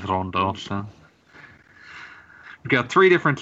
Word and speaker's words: Ronde: Also, it's spelled R-O-N-D-E, but the written English below it spells it, like, assures you the pronunciation is apparently Rondo.